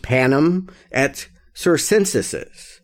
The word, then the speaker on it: panem et circenses: They called it bread and circuses, which, you know, forgive my Latin, but I believe it's pronounced panem et circenses.